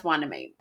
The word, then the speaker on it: Hueneme: It's spelled like Hueneme. I had to spell it out phonetically. Phonetically. – Oh my God. Forensically. Phonetically. I investigated myself. But it's pronounced Hueneme.